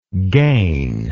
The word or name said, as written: gainable